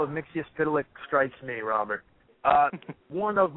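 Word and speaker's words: Mxyzptlk: Mr. Mitzius Titlick.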